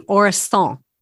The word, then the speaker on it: Orisson: Well, my understanding of the French language is they don't pronounce the N at the end. So it would be Orison.